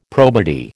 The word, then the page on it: probity: American English